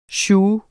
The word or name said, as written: SIOUXEN